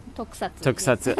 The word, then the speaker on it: tokusatsu: Tokusatsu.